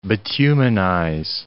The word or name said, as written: bituminize